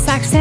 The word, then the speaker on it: Saxe: JP Saxey. – It was like S-A-X-E.